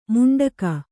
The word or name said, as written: ಮುಂಡಕ